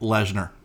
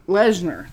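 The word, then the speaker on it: Lehnsherr: but how do you pronounce his last name? – Let me see it. – Eric Lesnar. – Lesnar, that's it.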